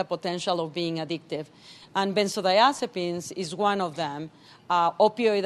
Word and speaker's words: benzodiazepines: Okay, this I had to write down because as a print journalist, I don't have to know how to pronounce benzodiazepines, right? – Benzodiazepines, yes.